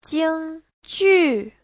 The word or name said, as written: jing1ju4